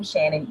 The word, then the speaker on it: Shannan: On Twitter, I'm my name, Shannan E. Johnson, because someone took the professional pin on Twitter as well. So I'm Shannan E. Johnson on Twitter, and my Shannan is spelled with 2 A's, S-H-A-N-N-A-N E.